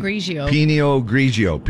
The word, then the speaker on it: Pinot Grigio: Pinot Grigio.